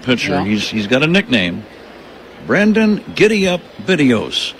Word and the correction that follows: Bidois: The last name is pronounced Bidwa. B-I-D-O-I-S. Bidwa.